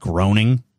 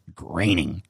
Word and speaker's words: Groening: His name is spelled Groening, but it's pronounced Groening. And at some point, I'm probably going to read it as Groening.